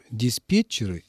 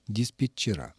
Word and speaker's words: диспетчеры: Валентина Зосимовна, для обоих слов правильной, абсолютно нормативной, литературной является форма с окончанием «ы» и с сохранением ударения такого же, как в единственном числе. Тренер – тренеры, диспетчер – диспетчеры.